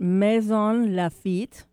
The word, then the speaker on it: Maisons-Laffitte: at Maisons-Laffitte, France. That's how you say it in French.